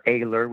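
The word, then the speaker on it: Ayler: And over the last, so I put out a CD last year, Mars Williams Presents an Eiler Christmas, or Eiler, whichever way you want to pronounce it. That's A-Y-L-E-R.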